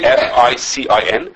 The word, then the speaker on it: ficin: Actually, an enzyme that we use nowadays is called, I don't know how to pronounce it, FICIN.